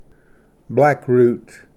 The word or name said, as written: ᏖᎾᏔᏁᏍᎩ